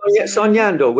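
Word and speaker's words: Soñando: Am I saying it right, Sonyando? – Yeah, Sonyando, which means dreaming in Spanish. Sonyando.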